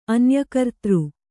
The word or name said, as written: ಅನ್ಯಕರ್ತೃ